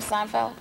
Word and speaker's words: Seinfeld: Is that how you say his name, Seinfeld? – Yes.